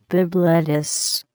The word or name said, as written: Bibb lettuce